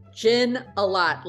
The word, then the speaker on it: Gignile: The easiest way to remember it is gin a lot. Like, I drink gin a lot, but I don't.